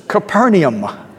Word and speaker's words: Capernaum: And in Greek, it's Capernaum. Don't leave out that extra syllable, Capernaum.